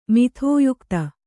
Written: ಮಿಥೋಯುಕ್ತ

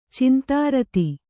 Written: ಚಿಂತಾರತಿ

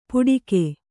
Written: ಪುಡಿಕೆ

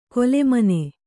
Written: ಕೊಲೆಮನೆ

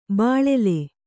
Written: ಬೀಳಿಲು

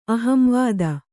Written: ಅಹಂವಾದ

